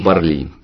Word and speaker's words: Парли: Парли. Парли. Нет, ну все слова в французском языке оканчиваются на ударение на последнее слово. Французы русские. – Придумали. Нет, это французы придумали. У них все на последний слог.